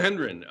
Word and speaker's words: Hendren: Am I pronouncing that right, Hendren? – Yeah, John Hendren.